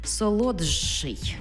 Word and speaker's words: солодший: Тобто пишемо «солодший», а вимовляємо «солодший».